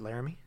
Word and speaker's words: Laramie: Before enrolling as a first-year student at the University of Wyoming in Laramie. – Laramie. – Is it Laramie? Okay. Sorry. on the accent on the wrong syllable.